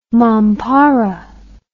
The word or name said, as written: mampara